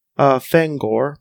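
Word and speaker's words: Phangor: The king of the Tetrons is Kaff, and then Fangor, but with a PH. Fangor with PH. Or Tangor. Like, because it's like the P, which is supposed to be, which is silent and pterodactyl.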